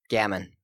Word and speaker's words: gamin: Gamin, yep. – Well, knowledge gained. – Synonyms: urchin, ragamuffin, waif, stray, or gutter snipe. – Gutter snipe is an underused word. – Gutter snipe. – Yes. – The gamins that inhabit the alley.